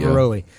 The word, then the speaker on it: Diveroli: Uh, how do you say his last name? Diveroli is that what we Diveroli, said? Diveroli. – Yeah.